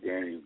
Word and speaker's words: Danyeil: Hit me up on Facebook, Daniel Green. My name is spelled DANYEIL, Green like the color.